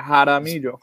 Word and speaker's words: Jaramillo: I'd say you're 90% there. Jaramillo.